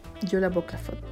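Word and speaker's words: Jólabókaflóð: La tradición se llama, vamos a ver si lo pronuncio bien, Yola Boclafot, no, Yola Bocaflot, Yola Boclafot, eso. Se las voy a deletrear por si la quieren buscar. J-O-L-A-B, B larga, O-K-A-F-L-O-D.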